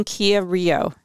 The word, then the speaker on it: Kia Rio: I love my 2015 Kia Rio.